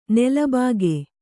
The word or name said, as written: ನಿಲಬಾಕಿ